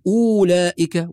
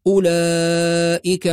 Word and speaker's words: أولئك: فلا نقول أولئك، وإنما الصواب أولئك على هدى من ربهم أولئك، هكذا بدون واو.